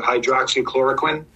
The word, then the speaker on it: hydroxychloroquine: And specifically, I'm going to butcher the pronunciation probably, but hydroxychloroquine. – You got it. – You did well.